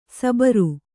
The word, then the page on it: ಸಬರು: sabaru